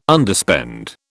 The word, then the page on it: Underspend: British English